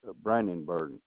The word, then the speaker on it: Brandenburg: Ronald, R-O-N-A-L-D, Brandenburg, B-R-A-N-D-E-N. B-U-R-G. – Spell that last name for me one more time, please. – B-R-A-N-D-E-N-B-U-R-G.